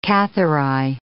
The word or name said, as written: Cathari